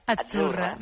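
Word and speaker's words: Azzurra: Achurra. – Mira, ves, achurra. – Es que... – Achurra. Sí, como porque pizza. La Z no es la Z como decimos en catalán tan sonora, no. Es achurra.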